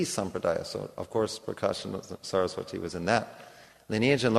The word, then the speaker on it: Saraswati: So the Prakashana Saraswati, actually it's a long I on the end, it's goddess Saraswati.